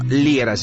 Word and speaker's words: Liera: Si me escriben en redes sociales, búscame por mi nombre, Fernando Liera. Se escribe L-I-E-R-A.